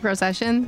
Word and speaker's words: Procyshyn: My Facebook is Kate Procession. That's my name. Yeah, it's really hard to spell, so good luck finding me. – It doesn't look anything like it's pronounced, but I'm terrible.